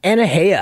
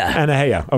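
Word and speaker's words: Anuhea: Sammy J, Cully Buds, I don't even know how to pronounce, Anuhia? – Anuhia. – Anuhia, okay.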